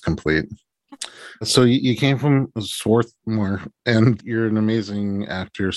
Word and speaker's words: Swarthmore: And Swarthmore, just so you know, is spelled Swarthmore, but when you're from there, the R is silent.